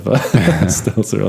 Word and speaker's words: Stelter: I don't think there's a Z in there. – Stelter. Like a seltzer.